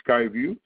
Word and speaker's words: SkyView: Let me talk about the exciting new announcement that you probably have seen has hit the press announcing SkyView, and we pronounce it SkyView, so it's an extension of our Sky platform.